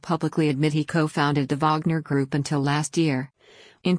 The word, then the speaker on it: Wagner: which means the name of the mercenary company is properly pronounced Wagner.